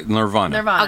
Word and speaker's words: Nirvana: Dr. Nirvana is... – Just like Nirvana. – Okay. Yeah. N-I-R-V-A-N-A.